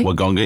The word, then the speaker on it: Wagonga: At the Naruma Sport and Game Fishing Club on Wagonga Inlet. Have I said that correctly? – Wagonga Inlet.